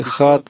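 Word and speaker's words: Ихат (אחד): Поэтому в слове Ихат две буквы Хет и Далет произносится продолжительно, то есть удлиняют произнесение буквы «далит».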